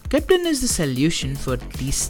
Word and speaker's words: Keptn: Keptn, pronounced as Keptn, is a control plane for DevOps automation of cloud native applications.